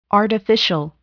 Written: artificial

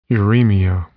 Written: uremia

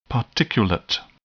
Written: particulate

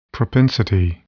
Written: propensity

